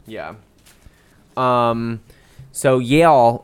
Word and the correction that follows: Yael: Y-A-E-L? – Yael.